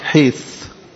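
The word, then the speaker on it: حيث (חית): كلمة عبرية تنطق حيث، والعبري قريب من العربي، فحيث جاية من حيطة أو صور.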